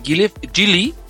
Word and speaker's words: Geely: Fíjate, es interesante porque Gilly, es importante primero empezar por eso, se dice Gilly. Eh, eh, Gilly, eh, exacto. En chino realmente se dice Gili, pero la pronunciación mundial en, en español, en inglés es Gili, como con Y.